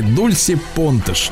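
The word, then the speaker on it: Dulce Pontes: называется, э, зовут ее, э, пишется Дуль, Дульце через Си, да, Дульса ее зовут, Понтес, это по-португальски Дульси Понтеш,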